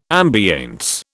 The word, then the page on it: ambiance: British English